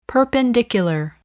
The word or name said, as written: perpendicular